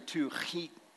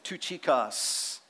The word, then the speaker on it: Tychicus: And it's actually Tychicus, like a guttural roll. But if we say that, we'll spray each other. So let's just call him Tychicus, a beloved brother and faithful minister in the Lord.